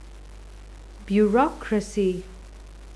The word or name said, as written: bureaucracy